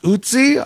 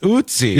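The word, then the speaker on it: Ötzi: Somebody says it's pronounced Oetsy. All right, we'll go with that. Oetsy. – Yeah, the O with the two dots over it, the German umlaut that sounds similar to the U in fur, mm-hmm, or the E in her, and then C. So, Oetsy. – So the Oetsy. – It says rhymes with Bootsy or Footsy, which is two different words to me.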